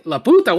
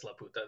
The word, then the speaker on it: Laputa: to be fair, English dubs do pronounce it in such a way that it is not easily said as La Puta. They call it Laputa, which is... fine.